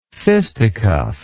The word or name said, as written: fisticuffer